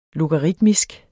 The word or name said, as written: logaritmisk